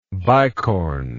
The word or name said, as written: bicorn